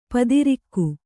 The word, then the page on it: ಪೈತೃಕ: paitřka